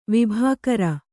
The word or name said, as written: ವಿಭಾಕರ